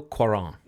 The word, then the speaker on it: Cuarón: Alfonso Cuarón.